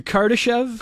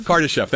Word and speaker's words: Kardashev: And we're trying to understand, we want to sort of, you know, go beyond the sort of Kardash, if I can pronounce it, or the Kardashov. – Hold on, the Kardashev? – Kardashev, thank you.